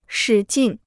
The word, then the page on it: 使劲: shǐ jìn